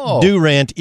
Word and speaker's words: Durant: It's in Durant, Oklahoma, pronounced Durant, even though it's spelled Durant. So Kevin Durant played, but the town is Durant, Oklahoma.